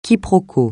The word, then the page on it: quiproquo: francais